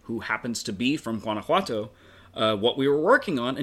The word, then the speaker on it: Guanajuato: Guanajuato. – Yeah, Guanajuato.